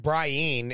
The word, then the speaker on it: Brienne: Brienne is in the is the proper pronunciation from the novels.